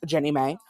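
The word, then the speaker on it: JNEMA: They sanctioned the proposal, and the nonprofit Jefferson National Expansion Memorial Association, pronounced Jenny May, the acronym, was formed.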